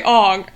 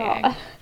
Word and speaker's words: Aang: Oh yeah, don't say Ong. Oh my god, are you okay, M. Night Shyamalan? – God. It's one of those ones where once it's pronounced wrong, I know it's pronounced, one of them is pronounced wrong, and then I don't remember which one is pronounced right anymore. – Oh no. – God. But it's Sokka. Aang. Yeah, it's definitely Aang.